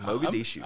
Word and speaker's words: Mogadishu: And that was right there, and it broke out, and it was in Mogadishu. Did I pronounce that right? – Yes, that was perfect. Mogadishu.